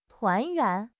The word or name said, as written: 团圆